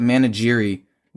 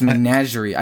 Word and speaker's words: menagerie: I always call it Managiri, but it's Menagerie.